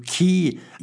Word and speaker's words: Cay: And so we set off, went to Florida, boarded ship down there and headed out to a place called Blue Cay or Blue Key. – Yep. – They call it C-A-Y, spell it there.